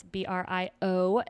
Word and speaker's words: Brionna Morse: So my Facebook, which is just my name, Brionna Morse, it's Brionna Morse, because a lot of people spell it wrong or pronounce it wrong.